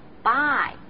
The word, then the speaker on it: bye: 但是发音的时候呢一定要注意，不要发成白色的白，是 bye，要圆润饱满。